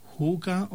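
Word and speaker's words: hygge: It is spelled H-Y-G-G-E. H-Y-G-G-E. But it is pronounced as huga or huga.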